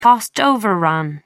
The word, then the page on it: cost overrun: American English